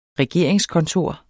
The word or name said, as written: regeringskontor